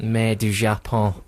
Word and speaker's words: Mer du Japon: Mer du Japon.